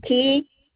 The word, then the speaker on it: Kee: Darlene Kee. It's spelled K-E-E. – K-E-E, is that your first name? – That's my last name.